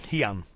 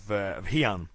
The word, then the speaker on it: Heian: And the original Okinawan pronunciation for the first ideogram is pin, where the Japanese pronounce it he. So therefore that's where we get the two pronunciations of pinan and hian.